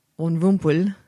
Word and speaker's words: Von Rumpel: And his search for, how do you pronounce it? – Von Rumpel.